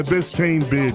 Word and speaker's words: Biscayne Bay: The Biscayne Bay, the bridge.